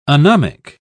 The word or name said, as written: anomic